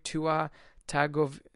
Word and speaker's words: Tua Tagovailoa: So the quarterback, they want to recruit Tua Tagovailoa.